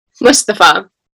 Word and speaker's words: Mustafa: Mustafa.